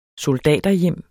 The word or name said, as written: soldaterhjem